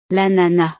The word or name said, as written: l'ananas